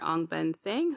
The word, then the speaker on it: Ong Beng Seng: So we have basically a situation happening in Singapore with property tycoon and billionaire Ong Beng Seng, who basically brought us a Singapore GP. – No. Stop. You pronounce that name like a pro.